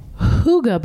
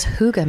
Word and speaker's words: Hygge: And then I also wanted to recommend the Hygge Box. – You said that, I think, correct. – Well, I Googled, how do you pronounce it? That's Hygge. That is how you say it, supposedly.